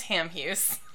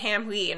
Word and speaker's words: Hamhuis: It's Hamhuis. – Hamhuis. Okay. Because I'm always going to say it Hamhuis in my head. Because it looks French.